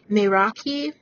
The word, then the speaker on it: Meraki: but it looks like it's pronounced Meraki. And it's spelled M-E-R-A-K-I.